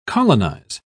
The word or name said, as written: colonize